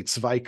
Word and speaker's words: Zweig: And I always say Zweig because that's the German pronunciation of the name.